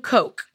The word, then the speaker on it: Koch: The guy that sued him is uh, William Koch. Hmm. Okay, so wait, uh, I know it's not spelled the same, but have you ever had Coke and wine together?